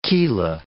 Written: chela

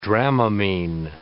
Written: Dramamine

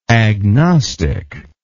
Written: agnostically

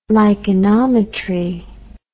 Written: lichenometry

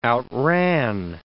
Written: outran

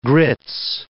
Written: grits

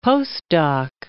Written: postdoc

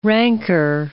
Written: ranker

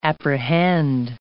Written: apprehend

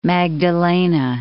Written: Magdalena

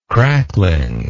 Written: crackling

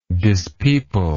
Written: dispeopler

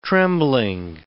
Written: trembling

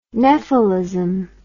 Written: nephalism

